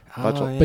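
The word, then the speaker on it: Bachalo: I'm going to mess up his last name because I think I've seen multiple pronunciations of it, but Chris Bocciolo? Bocciolo? – Bocciolo.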